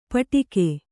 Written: ಪಟಿಕೆ

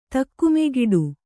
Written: ತಿಕ್ಕುಮುಕ್ಕಾಟ

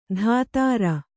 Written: ಧಾತಾರ

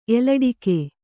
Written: ಎಲಡಿಕೆ

